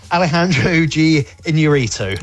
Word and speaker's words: Alejandro G. Iñárritu: Okay, well, it's the new film from, I can't pronounce this right, Alejandro G. Iñárritu.